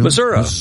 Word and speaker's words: Missouri: Missouri. Yeah, I worked for a news director one time who insisted it was called Missouri. You know, you got to pronounce it like the locals.